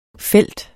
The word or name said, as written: felt